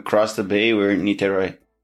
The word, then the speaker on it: Niterói: So, to get these started, you were born in Brazil, and I don't want to butcher the name, but Niterói? – Niterói, yeah.